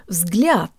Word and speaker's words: взгляд: guys, today you'll learn how to pronounce the word взгляд, meaning glance or view. Let's begin with the last three letters. The soft L almost sounds like it has a Y sound built in.